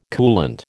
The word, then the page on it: coolant: American English